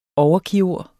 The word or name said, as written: overkirurg